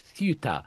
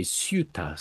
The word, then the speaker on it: Ceuta: The people on the Spanish mainland, they probably pronounce it something like Ceuta, but my Spanish pronunciation isn't that good. And in England, we say Ceuta, so that's what I'll say.